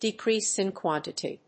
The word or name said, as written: decrease in quantity